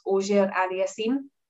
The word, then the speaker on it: Auger-Aliassime: I say Felix as well, because I don't want to make the same mistake, but I think it's Auger-Aliassime. – Auger-Aliassime. – Auger? Do you not pronounce the R? – It's Auger, right? – It's probably Auger. – Auger-Aliassime. – Francophone family, I think. – Yeah, Ogier Aliassime. That sounds about right to us.